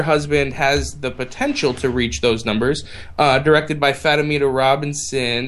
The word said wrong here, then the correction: Fatima: Fatima Robinson.